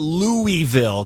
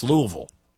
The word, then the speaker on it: Louisville: So just teaching you today, if I can teach you one thing, it's not Louisville because they hate that. It's Louisville.